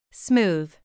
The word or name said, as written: smooth